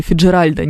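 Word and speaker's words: Фицджеральд: Кстати, по поводу, к примеру, фамилии Фиджеральд, очень мною нежно любимая, ударение на букву «Е». Никакого Фиджеральда или Фиджеральда не существует, и никакой Эллы Фиджеральд тоже не существует, она Фиджеральд.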